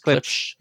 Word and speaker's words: Klipsch: How do you pronounce that? Klipsch? – Klipsch. – You got it.